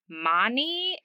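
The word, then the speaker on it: Máni: And I might mispronounce this god's name. I believe it is Mani.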